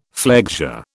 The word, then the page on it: flexure: British English